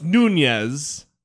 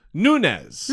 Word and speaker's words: Nunes: Apparently it's not pronounced Nunes. – Yeah. – Because there's not the little squiggly over the second N. – So how is it pronounced? – Nunes.